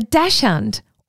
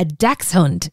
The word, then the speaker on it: Dachshund: The type of dog in this world record is called a Dachshund. Or a Dachshund is the correct pronunciation.